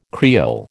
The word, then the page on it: Creole: American English